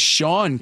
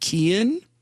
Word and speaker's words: Cian: C-I-A-N. How would you pronounce that? – C-I-Cain? – Yeah, I would go with Sean because it looks, it's actually pronounced Kian.